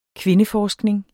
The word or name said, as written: kvindeforskning